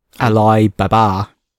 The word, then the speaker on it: Alibaba: Alibaba, how do you say it? – Alibaba. That's how we say it in China.